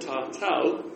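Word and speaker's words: tātau: So, ta-to, T-O-U, sorry. Ta-to. Ta-to, ta-to. It can actually be spelled ta-tau, ta-tau, a-u, in some, you know, it varies a bit, the spelling and the but the second a would be a short a.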